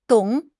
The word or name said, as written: dong3